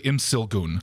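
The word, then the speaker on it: Imsil-gun: Tony Evers, I want you to direct one of the cities here to become sister cities with Imilgun. Sorry. – Is that how you pronounce it? Did you look it up? – Oh, no. It's actually Imsilgun.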